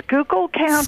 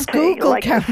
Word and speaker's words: Schuylkill: How do you pronounce that, Ellen? – That's Schuylkill County. – Schuylkill County. – Schuylkill River. – Right. I was always like Schuylkill. – I thought it was Schuylkill, but I'm glad now I know how to pronounce it.